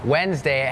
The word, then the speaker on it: Wednesday: ขอให้ใจเพื่อว่าเริ่มเลยก่อนเริ่มรายการพูดถึงคำว่าอะไรน้องๆจำได้ไหมคะ silent letters ใช่ไหมคะนั่นก็คือตัวอักษรที่ไม่ออกเสียงแต่อย่างคำนี้ค่ะเป็นคำหนึ่งที่มี silent letters Wednesday has that D W E D it's not wed Wednesday Wednesday D is silent